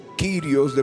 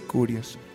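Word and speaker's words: curios (κύριος): Y en el griego, en el Nuevo Testamento, cuando decimos Jesús es el Señor, es, es o, kirios, depende cómo lo quieras pronunciar. Realmente debería ser curios, pero significa Señor.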